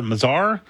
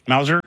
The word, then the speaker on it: Mazur: I know I'm pronouncing it wrong. M-A-Z-U-R. Mazar? Mazur? – Mauser.